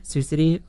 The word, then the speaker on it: Sioux City: And how do you say the city? – Sioux City. – Okay.